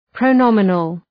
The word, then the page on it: pronominal: prəʋ’nɒmənəl